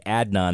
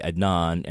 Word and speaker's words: Adnan: we've been mispronouncing Adnan Syed's name. We say Adnan, and it's really Adnan, and that's disrespectful to him.